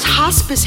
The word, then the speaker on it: hospice: You could be my whole spice. – That's pronounced hospice, Heffy.